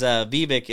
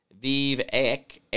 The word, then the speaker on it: Vivek: Ann says Vivek is pronounced Vivek, like the snake.